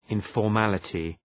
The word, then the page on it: informality: {,ınfɔ:r’mælətı}